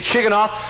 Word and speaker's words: Shigionoth: It's spelled S-H-I-G-I-O-N-O-T-H, Shigonoth.